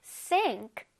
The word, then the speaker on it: sink: Sink, sink. 자, 그렇다고 싱크 똑같네요. 싱크 이러면 절대 안 돼, 얘들아. Sink. One more time. Sink. Okay. 힘을 줘서 발음해야 돼. Sink.